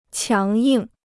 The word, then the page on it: 强硬: qiáng yìng